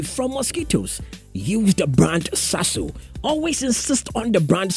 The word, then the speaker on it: Sasso: When they want to give you a different brand, tell them that Sasso is the brand I need to go for. It is spelled S-A-S-S-O. S-A-S-S-O. It's Waka Sasso.